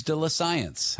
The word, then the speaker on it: de la science: I'm here with Jesse Rogerson, a professor at York University, to tell you about some fascinating news de la science. How's my French, Jesse? – Perfect. That was exactly the pronunciation.